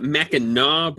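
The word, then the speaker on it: Mackinac: In Michigan, we actually call it Mackinaw, but I threw my name at the end of it, and so it becomes Mackinick.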